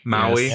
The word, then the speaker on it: Maui: It is the best way, all right, to show the two how completely crazy the heroes is when oh my god, Maui? I said that right? Maui. Maui.